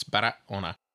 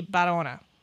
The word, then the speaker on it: Barahona: Just so you know, Barahona has an H in it in some of the spellings, but Spanish does not pronounce the H. So you'll also see spellings of it. – H is a break. It's not a sound in Spanish. It's Barahona.